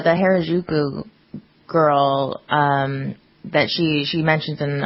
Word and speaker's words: Harajuku: How do you, it's spelled H A R A J U K U. Harajuku.